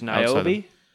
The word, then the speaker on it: Niobe: She's Niobe? – Yeah, that sounds right. – Did I say that right? – Yeah. – Pretty good.